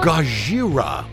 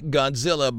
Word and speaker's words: Gojira: Gojira. That's actually how you say it. It's not Godzilla. It's Gojira. That's how you say it in Japanese.